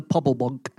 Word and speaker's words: pobble bonk: And the pobble bonk. – The what? – Pobble bonk. – Pobble bonk? – You have to say it like that.